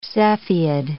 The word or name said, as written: Cepheid